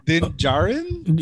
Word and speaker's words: Din Djarin: Din Djarin? – Yeah, Din Djarin.